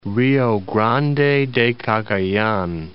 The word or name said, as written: Rio Grande de Cagayan